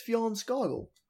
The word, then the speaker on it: Fjarnskaggl: But she's saying to me, she's telling me to say it properly, because with her, I've always pronounced it as Fjallskogl. – It's Fjallskogl. – I know, but I try and add as much guttural noise into it because it's the most ridiculous name, because it's spelled F-J-A-R-N-S-K-A-G-G-L.